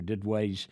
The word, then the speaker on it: Duduit: Lots of resources that you can access from there, including Dr. Didway's email newsletter, and his last name is spelled D-U-D-U-I-T, but pronounced Didway, and you can find him there.